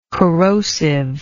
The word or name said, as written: corrosivity